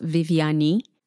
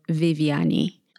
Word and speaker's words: Viviane: It's pronounced Viviane. A lot of Americans call me Vivian. Got to the point now that I don't correct people unless they ask me how I pronounce my name. So it's Viviane.